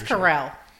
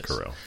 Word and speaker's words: Carell: In addition to, I think it's Carell. I think that's how people refer to him. – Yeah, it's Carell. – Yeah.